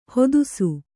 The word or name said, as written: ಹತ್ತಿಸು